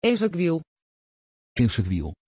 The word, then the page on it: ezequiel: Dutch